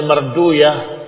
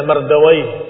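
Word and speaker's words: مردويه: وفي حديث عمرو بن عوف وهو عند ابن ابي حاتم dan juga ابن مردوي kadang mereka membacanya Marduyah tetapi kita membacanya Mardawi atau asal katanya Mardawi tapi mereka membacanya Marduyah seperti Sibawai mereka menyebutnya Sibuyah karena menghindari kata waih yang katanya waih maknanya jelek sehingga para ulama yang diakhiri kata waih mereka rubah didomahkan di awalnya.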